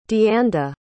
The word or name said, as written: deanda